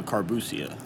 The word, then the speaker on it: Carbusia: How do you pronounce your last name? – Carbusia. – Carbusia. – Yes. – I wanted to say Carbuccia, but I was like, that's probably going to butcher it. – Oh, that's close enough. It's better than most.